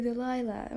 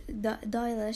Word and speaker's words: Dahlia: I always mispronounce that and say Delilah. Oh my god. Okay, her Black Dahlia, Dahlia, she got it from because of the movie The Blue Dahlia.